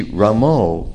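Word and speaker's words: Rama: On the other hand, Srila Prabhupada, you see, Ramo, this is a distinctly Bengali pronunciation.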